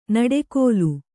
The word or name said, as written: ನುಡಿಗಲ್